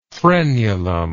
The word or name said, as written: frenular